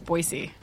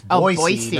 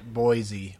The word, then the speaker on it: Boise: Do you know, once you go to Boise, they inform you very quickly that it's pronounced Boise. – Mm-hmm. – And I had no idea. – How do you say it? – Boise. – It's Boise, not Boise.